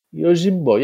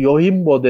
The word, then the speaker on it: Yojimbo: Yojimbo dedi diye şey yaptılar. Yani J Y şey J H okunur ya işte.